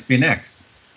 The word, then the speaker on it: Beineix: So this is Moon in the Gutter, 1983, directed by Jean-Jacques Benet. Benet, is that how you say his name? – You would think, but it's Benex.